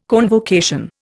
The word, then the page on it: convocation: Indian